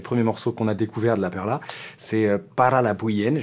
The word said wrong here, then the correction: Para la Bulla: je le prononcerai très mal parce que moi, je ne parle pas du tout espagnol. – Para la Bulla. – Para la Bullienne, ok, donc rien à voir.